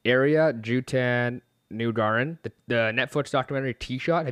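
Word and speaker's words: Ariya Jutanugarn: I've not seen that, but I know, very nice pronunciation. – Was that close? – A couple of pauses in there, but you are exactly right. Yes. It was Jutan Nugaran.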